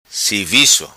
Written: Sivico

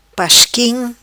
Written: Pasquim

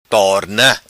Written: Torna

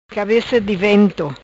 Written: Cabeca di vento

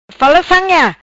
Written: fala sam ia

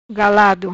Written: Galado

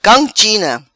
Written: Cam China

